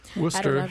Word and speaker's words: Worcester: It's not spelled Worcester, no, but it's it is not. pronounced that way.